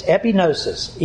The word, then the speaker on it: epignosis: It's epignosis, E-P-I-G-N-O-S-I-S. The G is silent. So the Greek word is epignosis.